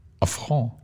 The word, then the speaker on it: Affront: Aber wenn es aus dem Französischen kommt, wie du sagst. – Dann wird es passen. – Und wie schreibt man Affront? – A-F-F-R-O-N.